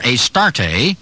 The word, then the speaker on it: Astarte: Astarte, with a silent E on the end, Astart,